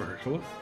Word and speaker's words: Mahershala: Yeah, I believe it's Mahershala.